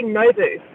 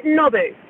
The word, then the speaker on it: Nobu: Can you give us a Japanese pronunciation of the restaurant N-O-B-U? – Yeah, Nobu.